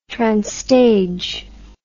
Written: transtage

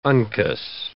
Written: uncus

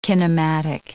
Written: kinematic

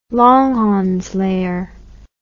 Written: Langhans layer

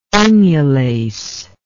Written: inulase

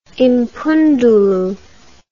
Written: impundulu

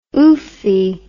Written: oofy

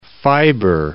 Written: fiber

